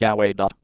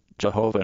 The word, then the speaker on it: Yahweh: This is the meaning of the Israelite name for God, YHWH, probably pronounced Yahweh. The name Jehovah in earlier translations was a misunderstanding.